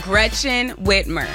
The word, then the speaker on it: Gretchen Whitmer: Gretchen Whitmer.